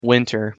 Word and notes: winter: English

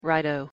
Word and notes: righto: English

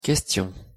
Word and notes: question: French

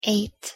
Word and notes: eight: English

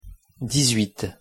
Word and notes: dix-huit: French

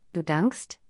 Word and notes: du dankst: German

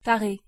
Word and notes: taré: French

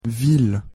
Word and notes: ville: French

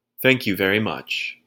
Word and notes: thank you very much: English

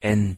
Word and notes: n: German

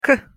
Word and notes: к: Russian

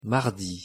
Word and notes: mardi: French

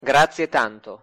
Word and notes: grazie tanto: Italian

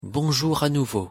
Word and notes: bonjour a nouveau: French

